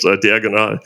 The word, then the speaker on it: Diagonal: I'm pronouncing it more Spanish. Diagonal.